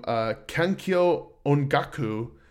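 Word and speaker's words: Kankyō Ongaku: The other one that I want to talk about is this album, Kankyo Ongaku.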